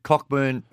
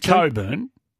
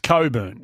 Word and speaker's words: Cockburn: It's Coburn is how you pronounce it. – He spelled it. – I know. Well, that's how it's spelled. – Oh. – It's a silent CK, though.